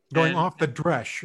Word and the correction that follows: derech: is that how it's pronounced? – Off derech, the dresh. Off the derech.